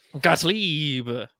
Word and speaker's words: Gottlieb: Said it correctly, Gottlieb. It sounds like Gilbert Gottfried, RIP, but Gottlieb.